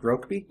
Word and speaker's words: Rokeby: So I'd like to talk about, it's pronounced Rokeby? – Yes.